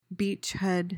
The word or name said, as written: beachhead